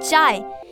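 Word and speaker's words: Qi: अगर कोई Chinese word या नाम में इंग्लिश का क्यू लेटर आता है तो उसे च प्रोनाउंस करते हैं। जैसे क्यू आई को कहते हैं चाय, क्यू आई एन को कहते हैं चाइन।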